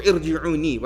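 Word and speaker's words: irji'un (ارجعون): So, the first, Allah Ta'ala says, when death reaches them, they'll say, Rabbi irji'un, or irji'uni, right? The tajweed cuts off the you pronounce it as irji'un.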